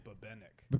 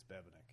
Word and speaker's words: Bebenek: A lot of people want to emphasize the second syllable. So they really? – Say Bebenek. – Bebenek. – But it's Bebenek. Yeah.